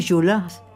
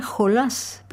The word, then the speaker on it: Jolas: Inauguramos junio y he programado una suite de Betsy Jolas. Se escribe Jolas, pero es francesa y en su apellido la S final se pronuncia.